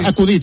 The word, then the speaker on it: acudit: Acudit, se dice acudit, pero hasta Morata es sacudí.